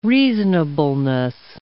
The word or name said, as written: reasonableness